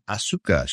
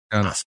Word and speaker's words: Asuka: That's why Asuka is not Asuka. She's Asuka. Yeah, just a little lesson.